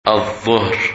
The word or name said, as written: Dhuhr